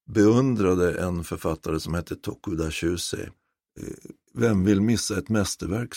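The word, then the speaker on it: Tokuda Shūsei: Han heter Tokuda Shusei, född 1872, dog i november 1943.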